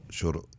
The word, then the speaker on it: Shotokan: My father did, and, I may not be pronouncing it correctly, but is Shotokan. – No, Shotokan. Yes.